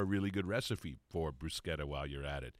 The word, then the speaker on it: bruschetta: And you even point out, and I appreciate the fact, that it's pronounced bruschetta, not bruschetta.